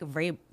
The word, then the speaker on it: Vrabel: The big news in the NFL yesterday was the Titans firing Mike Vrabel. – That's right. – Yeah, I said it right. That V is there for no reason.